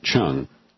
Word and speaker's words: Chiang: He spells his name C-H-I-A-N-G and pronounces it Chung.